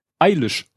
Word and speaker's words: Eilish: Äh, der Henrik Klar schrieb, die Frau wird as far as I know eilish ausgesprochen wie in ich aber es eilish.